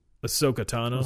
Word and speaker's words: Ahsoka Tano: Soko, Soku, Ahsoka Tano.